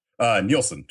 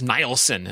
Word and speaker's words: Nielsen: Nielsen, just like the rating system.